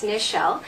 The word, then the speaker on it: Nichelle: My name is Nichelle Thompson, so that's N as in Nancy. A lot of people always call me Michelle, but it's Nichelle.